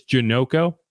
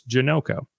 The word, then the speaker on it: Janocko: I figured out how to say it. It is Janocko. So in case anyone was wondering and why I kept vacillating between Janocko and Janocko, it's Janocko. It's simple. But so, you know, this is a guy who has familiarity with Clint Kubiak.